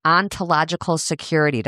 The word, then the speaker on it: ontological security: Ontological security. Did I say it right? – You did indeed.